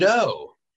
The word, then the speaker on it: Sardo: Sardo. – No, yeah. Has no mister accent on the toe. What's say it the way it's spelt.